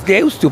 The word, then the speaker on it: Deusto: Es Deusto, porque se escribe con U final.